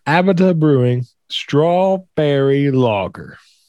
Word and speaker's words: Abita: I'm assuming that's how you pronounce it. – Abita. – Is it Abita? – Abita. – Abita? – Yeah, Abita.